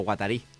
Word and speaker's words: Guattari: O Guattari, ben pronunciat.